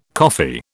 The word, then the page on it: coffee: British English